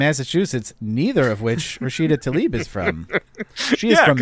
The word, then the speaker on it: Tlaib: censured Tlaib. Is that how you pronounce that? – Rashida Tlaib. – Tlaib. Okay.